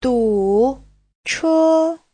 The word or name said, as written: du3che1